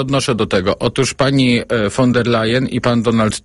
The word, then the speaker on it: von der Leyen: Ale chciałbym też przypomnieć, jak pani Ursula von der Leyen, chyba nie umiem wymawiać tego. – Nie, właśnie pan umie. Wielu nie umie, mówiąc von der Leyen, a pan dobrze mówi, von der Leyen.